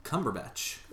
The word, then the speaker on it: Cumberbatch: How do you say his last name? – Cumberbatch. – I said that right.